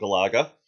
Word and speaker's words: Galaga: Yes, I'm talking about Galaga.